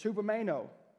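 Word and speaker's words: hupomeno (ὑπομένω): Now, this word is hupomeno. That's how you say it in the Greek. We say hypo now for under, right? When something's hypoglycemic, low sugar, right? It's the same word, it's just actually pronounced hupo than it is hypo.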